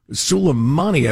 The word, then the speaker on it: Soleimani: And that's the story of, remember when we killed old Salamander? The Iranian general? – Soleimani, I believe, is the pronunciation, but go on.